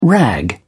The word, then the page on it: rag: British English